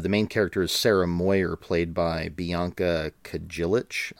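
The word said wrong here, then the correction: Kajlich: I'm hoping I pronounced that right. – Kylik. – Oh, it's pronounced Kylik? – It's Kylik.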